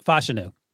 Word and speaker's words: Fashanu: Fashanu. – Fashanu.